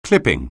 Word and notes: clip: anglais

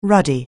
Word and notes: ruddy: anglais

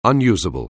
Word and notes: unusable: anglais

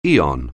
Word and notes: aeon: anglais

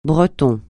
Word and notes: Breton: francais

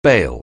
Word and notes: bail: anglais

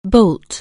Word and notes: bolt: anglais

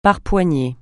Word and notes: par poignées: francais

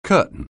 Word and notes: curtain: anglais

